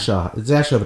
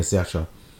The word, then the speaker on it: Saoirse: Nee, ich habe jetzt Saoirse oder so gesagt. Saoirse wird das ausgesprochen, glaubt mir, Saoirse.